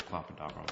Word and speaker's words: clopidogrel: There's no evidence or argument that turns a poor clopidogrel responder into a good clopidogrel responder.